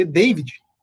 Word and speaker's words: Davi: Depois o David, se escreve Davi, mas se lê David, fez o 2 a 0 pro Fortaleza aos 33 do segundo tempo.